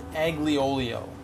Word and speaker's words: aglio olio: The next sauce is aglio olio, pronounced A-G-L-I-O-E-O-L-I-O.